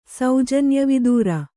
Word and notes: ಸೌಜನ್ಯವಿದೂರೆ: saujanya vidūre

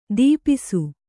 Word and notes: ತಪಸು: Kannada

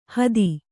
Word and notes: ಹಾದಿ: kannada

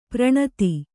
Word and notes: ಪೌರನೀತಿ: kannada

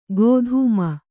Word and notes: ಗೋಧೂಮ: gōdhūma

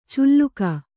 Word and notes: ಚುಲ್ಲುಕ: culluka

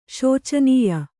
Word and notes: ಶೋಚನೀಯ: śocanīya

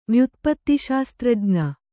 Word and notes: ವ್ಯುತ್ಪತ್ತಿಶಾಸ್ತ್ರಜ್ಞೆ: kannada

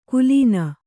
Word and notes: ಕುಲೀನ: Kannada

